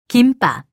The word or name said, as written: 김밥